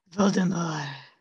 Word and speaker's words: Voldemort: Or Voldemort, sorry, we've been saying it wrong the whole time. It's French, the T is silent. – Voldemort.